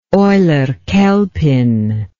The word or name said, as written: eulerchelpin